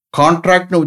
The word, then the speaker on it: contract: கண்ட்ராக்ட், அதுவும் சுருக்கிறது தான். கண்ட்ராக்ட், CONTRACT, கண்ட்ராக்ட், கண்ட்ராக்ட், கண்ட்ராக்டட், கண்ட்ராக்டட். கான்ட்ராக்ட்னு உச்சரிச்சுன்னா அது வேற ஒப்பந்தம், கான்ட்ராக்டு, புரியுதா? டெண்டர்லாம் எடுக்கிறாங்கல்ல, கான்ட்ராக்ட் ஒர்க்கு, அது வேற, அதே ஸ்பெல்லிங்ஸ் தான். பட் இந்த மாதிரி உச்சரித்தோம்னா, இந்த மாதிரி ப்ரொனவுன்ஸ் பண்ணோம்னா குறைக்கிறதுனாயிரும். கண்ட்ராக்ட், கண்ட்ராக்டட், கண்ட்ராக்டட்.